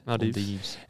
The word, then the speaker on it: Maldives: Maldives.